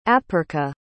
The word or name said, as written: apirka